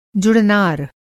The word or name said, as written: जुड़नार